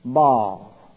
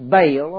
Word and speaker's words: Baal: Baal. Better known as Baal or Baal. B-A-L, B-A-A-L. I always pronounce it Baal, but they pronounce it Baal on my tapes, on my Bible tapes.